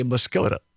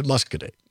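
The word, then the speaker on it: Muscoda: Some people will say Muscogee, which is incorrect. – It's spelled like that. – It is not correct. It is Muscogee.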